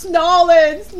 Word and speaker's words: New Orleans: I'm like, no, it's Nolens. New Orleans. Say it like the local.